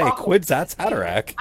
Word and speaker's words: Kwisatz Haderach: Kwisatz Haderach.